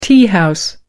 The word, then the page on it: teahouse: American English